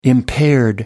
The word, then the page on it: impaired: British English